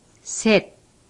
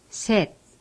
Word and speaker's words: 셋: For example, 셋. – Repeat. – 셋, 셋.